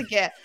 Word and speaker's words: GIF: And I think I pronounce, is it gif? Do I pronounce it wrong? – I believe it's gif. – You believe it. Okay, yeah, good. – I think it's a hard G.